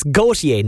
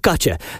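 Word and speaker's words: Gotye: That's Gautier, not Gotcha.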